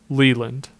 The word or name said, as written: LeLand